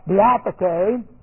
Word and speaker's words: diatheke: Looks like this, K-A-I-N-O-S, translated new, right here, translated new. And then we have the Greek word, diatheke, which for covenant. Diatheke, and we're going to study this a little bit, D-I-A-T-H, long E, and then we have K, long E.